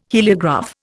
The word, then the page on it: heliograph: Indian